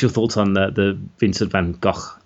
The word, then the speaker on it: Van Gogh: In this episode, the Doctor, Amy, and, of course, Vincent Van Gogh, and that's how you say it, and a terrified alien all feature in this stunning but heartbreaking adventure written by Richard Curtis.